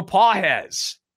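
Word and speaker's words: Pagés: Pedro Pajes, spelled Pages, but Pajes is how it's pronounced, hitting .125 in 11 games.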